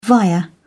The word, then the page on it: via: British English